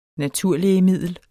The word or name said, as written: naturlægemiddel